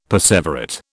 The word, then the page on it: perseverate: British English